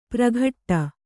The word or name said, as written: ಪ್ರಘಟ್ಟ